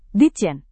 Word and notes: ditjen: Indonesian

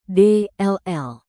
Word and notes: dll: Indonesian

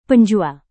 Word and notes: penjual: indonesian

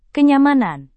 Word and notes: kenyamanan: indonesian

